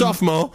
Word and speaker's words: sophomore: Apparently, also we've discovered it's a silent O, so it's just it's a sophomore.